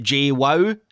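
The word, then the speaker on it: João: It's, I believe you pronounce it J-Wow, is how you say Joao.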